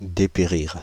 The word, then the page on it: dépérir: /de.pe.ʁiʁ/